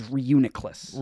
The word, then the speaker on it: Reuniclus: Do you know how to actually pronounce it? But it is Reuniclus.